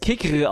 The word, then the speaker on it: Kicker: Also man kann es einfach Kicker aussprechen, aber eigentlich muss man es Kicker aussprechen.